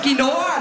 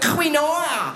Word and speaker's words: quinoa: Es heisst Quinoa, nicht Quinoa.